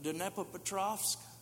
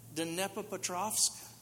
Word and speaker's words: Dnepropetrovsk: Week before last, I was in the Ukraine, in Kiev, and Dnepropetrovsk. I'm going to give you a test to see if you can pronounce that. Let me say it one more time. Dnepropetrovsk. Took me 5 years to get it down.